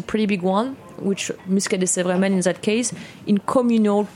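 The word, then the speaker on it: Sèvre-et-Maine: How do you pronounce S-E-V-R-E? – Sèvres-et-Maine. – Sèvres-et-Maine.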